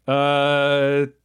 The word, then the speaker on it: Schwedel: Schwedel.